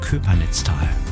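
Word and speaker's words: Köppernitztal: Milmersdorf, Betonstraße, Neujabel Richtung Wosmerhof, Ortseingang Torgelow von Diepgarten aus, dann Philosophenweg stadtauswärts in Wismar und von der Werft kommend in Wismar Köpernitztal, obwohl man es mit Doppel-P schreibt, ne? Eigentlich Köpernitztal, aber ausgesprochen Köpernitztal.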